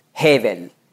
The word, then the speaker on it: Hevel: Auf Hebräisch spricht man das aus, Hevel.